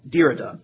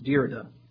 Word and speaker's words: Derrida: Actually, that's not right. Derrida. That's how you pronounce it. Derrida.